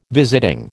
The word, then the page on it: visiting: American English